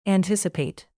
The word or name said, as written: anticipate